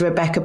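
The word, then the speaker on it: Rebekah: My full name is Rebecca Pauline Clark.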